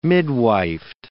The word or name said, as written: midwifed